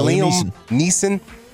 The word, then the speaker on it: Liam Neeson: Am I saying his name right? – Yeah, Liam Neeson.